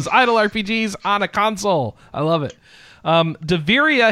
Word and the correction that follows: Davyria: Heroes of Eternal, Here is Daviria Heroes of Eternity. That is spelled D-A-V-Y-R-I-A.